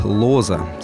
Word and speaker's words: Лоза: Именно так Лоза с ударением на первый слог правильно произносится его фамилия.